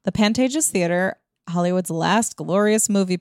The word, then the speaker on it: Pantages: Pantages? – The Pantages, yeah.